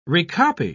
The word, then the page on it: recopy: British English